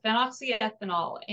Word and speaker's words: phenoxyethanol: but it's called phenoxyethanol. Yes. And so if anybody wants to look it up, it's spelled P-H-E-N, Phen, Oxy, O-X-Y, Ethanol. So phenoxyethanol.